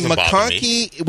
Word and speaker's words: McConkey: I mean, McConkey, which rhymes with honky, and Pearsall.